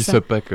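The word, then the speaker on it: SubPac: Je ne sais pas si je le prononce bien. – Chacun le prononce comme il veut, nous on dit sub-pack en tout cas.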